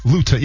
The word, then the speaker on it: Luton: Yeah, rhymes with gluten, not crouton. – Exactly. Or bouton, if you will. Luton.